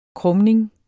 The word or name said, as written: krumning